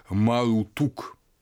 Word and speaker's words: Марутук: Слово «малдук» или э, на западно семитской манер Мелодах видимо, в полной э, форме читается как «марутук».